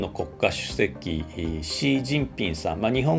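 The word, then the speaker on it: Xi Jinping: 英語ではあの中国語発音に近いシー・ジンピンという呼び方をしましてその C が XI と書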